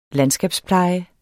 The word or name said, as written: landskabspleje